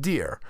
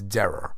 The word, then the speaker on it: Derer: Problem is, his last name is Derer, and I always call him Deer. So you might think it's spelled Deer like Deer Charlie, Deer John, or I just hit a deer. But it's not. It's Derer.